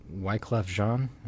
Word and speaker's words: Wyclef Jean: Okay, I'll probably script the pronunciation on this one, but Wyclef Jean, is that right? – That's pretty good.